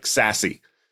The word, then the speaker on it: SASE: And because no acronym in IT can be spelled out, it must be pronounced. Someone said, that looks like SASE.